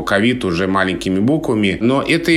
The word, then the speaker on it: ковид: Еще были вопросы, когда только-только появилось это слово, как правильно говорить, ковид или ковид. Но кажется, что сейчас даже этот вопрос уже можно и снимать, потому что ковид употребляется в подавляющем большинстве случаев именно с таким ударением.